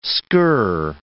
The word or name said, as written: skirr